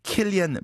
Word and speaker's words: Cillian: 예를 들어서 Saoirse Ronan, S-A-O-I-R-S-E, R-O-N-A-N. 이 왜 Saoirse Ronan인지. 그다음에 Cillian Murphy, C-I-L-L-I-A-N Murphy가 왜 Cillian Murphy인지.